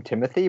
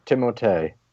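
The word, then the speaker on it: Timothée: And as I just learned the other day, people call him Timothy, but that's not how his name is pronounced, actually. – I, I, I can't. – I've tried. Timotei.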